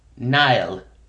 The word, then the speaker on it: Niall: Niall, spelled NIALL.